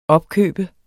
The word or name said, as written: opkøbe